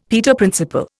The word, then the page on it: Peter Principle: Indian